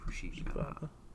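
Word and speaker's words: Fushigibana (フシギバナ): Probably you pronounce the A's less than that. It's probably more like Fushigibana.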